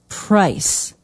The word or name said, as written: price